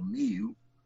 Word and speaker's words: Muil: And his name was Rory Mew, spelled M-U-I-L, but pronounced just like the animal, Mew.